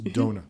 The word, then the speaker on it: DONA: So that's spelled D-O-N-A. – Yes. – It's pronounced Donor.